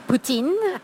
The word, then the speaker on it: poutine: Poutine.